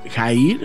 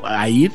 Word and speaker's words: Hair: Se pronuncia Jair o Air con H, Boards. Hair Boards.